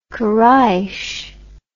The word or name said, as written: quraish